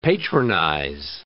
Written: patronize

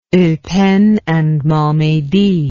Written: eupen and malmedy

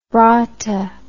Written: braata

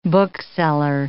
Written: bookseller

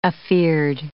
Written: afeard

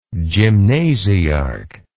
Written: gymnasiarch

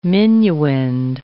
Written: minuend

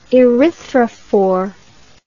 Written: erythrophore

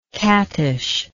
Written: cattish

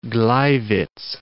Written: Gleiwitz

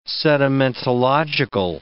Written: sedimentological

